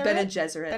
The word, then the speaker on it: Bene Gesserit: Bene Gesserit.